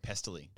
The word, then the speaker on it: Pestily: Okay, so then I'm on Twitch a week ago, and this guy, Pentley, is that how you say his name? – Yeah, Pestily. – Pestily? Yeah. Pestily, sorry. Is getting 100K concurrents.